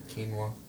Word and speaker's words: quinoa: Is it like quinoa, something like that? – Yeah, quinoa.